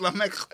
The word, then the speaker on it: Lamech: Lamech. It's Lamech.